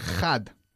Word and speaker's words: had: Lo importante, por supuesto, es no decir o pronunciarlo had con una J fuerte, porque no hay J aquí, ¿ok? Piensa en la forma en que dicen los gaditanos la J.